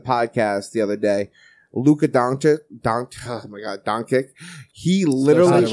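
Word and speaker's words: Dončić: Doncic. No, say the man's name right. We're going to talk about it. Doncic. – Doncic. There you go.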